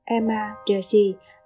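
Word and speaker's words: Ema Datshi: amma Deshi, phát âm Emma Deshi, là một loại thức ăn được ưa thích tại Bhutan.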